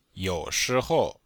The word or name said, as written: you3shi2hou5